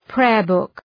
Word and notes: prayer book: ‘preərbʋk

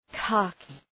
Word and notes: khaki: ‘kækı

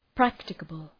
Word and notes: practicable: ‘præktəkəbəl

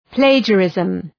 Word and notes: plagiarism: ‘pleıdʒə,rızm

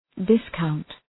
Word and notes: discount: dıs’kaʋnt

